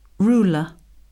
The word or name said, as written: ruler